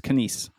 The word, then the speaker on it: Canis: It's Canis. It's the stress on the last syllable, and it's surprisingly hard to get right.